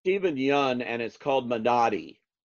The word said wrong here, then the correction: Minari: it's movie. like this. It's me. – It's Minari. – Minari. Okay. Minari.